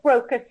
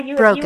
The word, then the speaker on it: Brocas: Go to the little search box, and you can just put in Joanne, J-O-A-N-N-E. Last name is Brocas. Yes. Am I pronouncing that right? – Yeah, it's Brocas. Yeah, Brocas. You are.